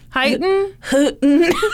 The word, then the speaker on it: Huyton: Houghton. – H-U-Y-T-O-N, which is outside of Liverpool. – You think H-U-Y-T-O-N would be pronounced Houghton? – How would you say it? Houghton? – Houghton.